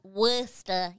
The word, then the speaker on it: Worcester: Well, don't they also have towns that look like they're supposed to be pronounced Worcester and they're pronounced Worcester? – Worcester.